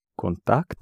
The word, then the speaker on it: контакт: Контакт. – Контакт. Mm-hmm. Yes, it's the ending that is accented, so you pronounce контакт.